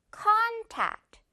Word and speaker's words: contact: con의 강세 해주세요. contact. contact. 이건 정말 많은 친구들이 contact 이렇게 잘못 발음하는 단어예요.